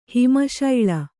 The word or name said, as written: ಹಿಮಶೈಳ